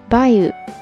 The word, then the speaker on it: 梅雨: 虽然写法没啥区别，不过那时候应该还是读作 bayu 梅雨。